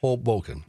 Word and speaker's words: Hoboken: Give the accent to the first syllable. – Hoboken.